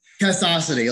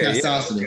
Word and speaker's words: Castacity: Castacity, like velocity? – Okay. Castacity.